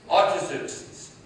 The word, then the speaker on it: Artaxerxes: It takes me a moment to try and get the pronunciation of his name. No, it's Artaxerxes.